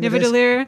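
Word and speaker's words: Nidavellir: I think that there's a small detail that comes up with the character of Eitri on, I'm going to say it wrong, Nilidhivar. – Nivadilir, I think Nivadilir. – It is.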